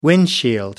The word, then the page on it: windshield: British English